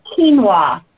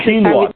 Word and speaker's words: quinoa: The word you were looking for earlier was quinoa. – Yes, quinoa. Thank you. – It doesn't sound like it's spelled.